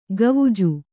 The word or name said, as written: ಖ್ವಾಜಾ